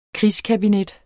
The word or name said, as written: krigskabinet